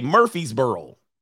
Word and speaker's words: Murfreesboro: Am I saying it right? Murfreesboro. Okay, Murfreesboro. Murfreesboro.